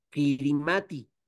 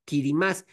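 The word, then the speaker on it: Kiritimati: Su isla más grande se escribe Kirimati, pero se pronuncia Kirimás.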